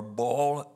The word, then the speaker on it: Baal: If they had a place of worship, such as Baal or Baal, as some pronounce it, there was a statue such as Dagon.